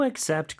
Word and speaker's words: accept: Do you accept credit cards? – 네, accept의 마지막 T 발음 살짝 해주는 거. 그 다음에 credit, CR 발음. 그 다음에 cards의 마지막 Z, 요 발음 잘 기억해 주시면서 연습 많이 해주시면 돼요.